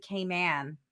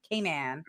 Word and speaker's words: Cayman: I went to Cayman Island last week, which I learned that you pronounce it Cayman. That's how you say it, like Pac-Man, Cayman.